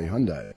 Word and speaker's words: Hyundai: And the Hyundai, I don't know, did I pronounce that right? Hyundai? Did I pronounce that? Hyundai. Okay, there you go, Hyundai. – Yeah, I would just say Hyundai.